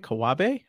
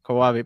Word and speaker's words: Kawabe: Today, I have Hugh, and I hope I'm saying your last name right, Kawabe. How do you say your last name? – Perfect. Nah, it's good. Kawabe. – That's perfect. Yeah. Kawabe, perfect.